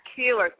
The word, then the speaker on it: Keillor: Robin and Linda Williams and Garrison Keillor. Did I say that right? – Keillor.